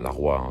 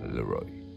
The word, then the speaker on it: Laroi: Enfin, la roi, hein, si euh, vous le prononcez à la française, mais nos amis anglo-saxons appellent ça Leroy.